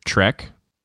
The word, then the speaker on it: Trzech: Tara was drinking a, a pan I panini by, now this is going to sound weird, Trek, spelled T R Z E C H.